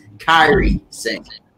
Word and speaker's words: Kairi Sane: Nia Jax versus Carly Sane. Now, have I got that name right? – Andrew, I tell you this, it's pronounced Kairi Sane.